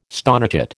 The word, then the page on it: stonechat: American English